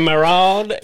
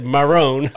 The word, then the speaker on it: Maron: Mark Maron. I think you said Marone or something like that.